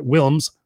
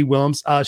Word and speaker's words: Willms: And if you haven't seen that episode before, her name's Peggy Williams, and Wilms. Got to make sure we say that correctly. W-I-L-L-M-S, Wilms, Peggy Wilms.